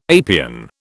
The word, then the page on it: apian: British English